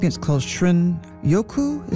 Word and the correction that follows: shinrin-yoku: Is that how you pronounce it? The Japanese term for wood air bathing. – Shinrin-yoku.